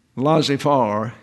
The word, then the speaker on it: laissez-faire: Laissez-faire is the way to pronounce that.